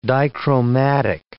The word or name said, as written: dichromatic